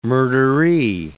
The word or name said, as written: murderee